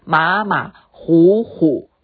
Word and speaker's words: 马马虎虎: 马马虎虎，我们是念成呼呼吗？不是，它是马马虎虎，那个字就是老虎的虎。